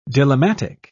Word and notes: dilemmatic: British English

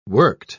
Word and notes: worked: British English

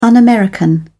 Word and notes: un american: British English

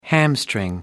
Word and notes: hamstring: American English

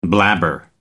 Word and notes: blabber: American English

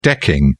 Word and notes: decking: British English

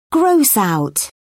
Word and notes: gross out: British English

